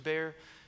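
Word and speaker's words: Behr: And so I wrote on a piece of paper, and I Mr. B-E-H-R. I said, this is his name. It's pronounced Mr. Bear, and it's like French or something.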